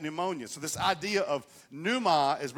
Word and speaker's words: pneuma: We would say pneuma. I think they actually pronounce the P in Greek, so it's pneuma.